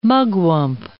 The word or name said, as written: mugwump